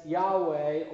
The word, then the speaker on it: Yahweh: Is a name that is sometimes, in our English, pronounced as Yahweh or Jehovah.